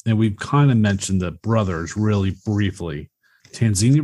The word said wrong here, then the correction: Terenzi: brothers? Am I saying that right? Terenzi brothers? – Terenzi. – Yeah, the Terenzi brothers.